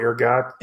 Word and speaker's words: ergot: It wasn't, but there was a fungus on wheat called, it's spelled E-R-G-O-T. I don't know if it's ergo or ergot. – It's ergot, yeah.